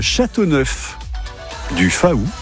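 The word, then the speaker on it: Châteauneuf-du-Faou: C'est à Châteauneuf du Faou. – Vous êtes sûr qu'on prononce comme ça? – Oui, hein.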